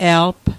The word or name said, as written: elg